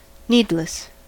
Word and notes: needless: American English

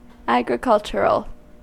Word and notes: agricultural: American English

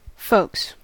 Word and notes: folks: American English

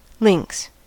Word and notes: lynx: American English

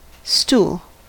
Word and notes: stool: American English

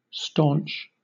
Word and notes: staunch: British English

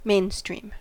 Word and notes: mainstream: American English

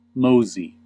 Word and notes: mosey: American English

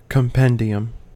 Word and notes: compendium: American English

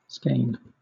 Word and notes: skein: British English

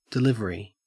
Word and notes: delivery: Australian English

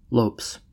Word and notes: lopes: American English

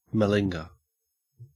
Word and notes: malinger: Australian English

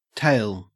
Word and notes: tale: Australian English

